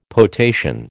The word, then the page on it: potation: American English